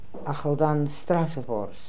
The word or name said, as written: Akhaldanstrassovors